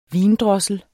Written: vindrossel

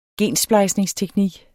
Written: gensplejsningsteknik

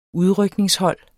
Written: udrykningshold

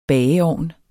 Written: bageovn

